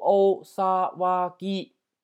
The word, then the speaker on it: お騒ぎ: 还有一个叫大骚，大骚读成お騒ぎ啊，お騒ぎ。哦哦萨瓦吉，哦萨瓦吉啊，这样的，具体怎么写？你把这几个假名打出来，你就会知道怎么写了。